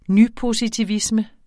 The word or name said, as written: nypositivisme